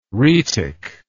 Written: raetic

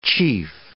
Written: chief

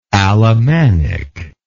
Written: Alamannic

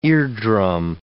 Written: eardrum

